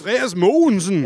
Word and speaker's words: Andreas Mogensen: Hva, hvordan pleier du å uttale navnet hans? Andreas Mogensen.